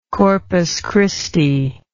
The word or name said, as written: Corpus Christi